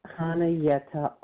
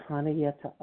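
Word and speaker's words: Chanayetta: Yes, I am Hanayeta R., and I'd like to spell that today, if you don't mind. C-H-A-N-A-Y-E-T-T-A-R from Texas. And the C is silent when you say my name. So it's Hanayeta R., and I am a recovered compulsive overeater, and I'm really glad to be here in remission today.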